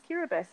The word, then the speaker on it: Kiribati: K-I-R-I-B-A-T-I, but it's pronounced Kiribati.